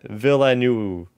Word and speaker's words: Villeneuve: We got a pronunciation from Sean Stretton in the chat. Villeneuve. Okay, Denis Villeneuve.